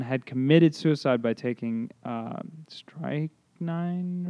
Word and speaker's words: Strychnine: Strychnine. – That's how you say it. Strychnine.